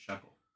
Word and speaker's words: Shuckle: Rhymes with Uckle because his name is Shuckle.